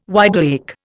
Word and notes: wide awake: Indian

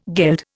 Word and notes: geld: Indian

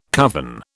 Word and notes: coven: British English

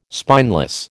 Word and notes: spineless: American English

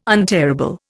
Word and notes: untearable: Indian

